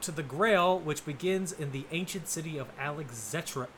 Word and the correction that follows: Alexandretta: It's How do you say this? It's Alexandretta. That's it.